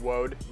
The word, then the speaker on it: Woad: Woad. W-O-A-D.